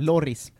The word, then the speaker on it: Lloris: Jag skulle ju säga tvärtom, att jag tycker att Hugo Lloris är en trea.